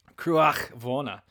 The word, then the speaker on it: Cruach-Mhòna: So Cruach-Mhòna, which is spelled a lot like Cruach-Mhòna. So there's an MH in there. So it's like, Moana, Mahona, My Sharona.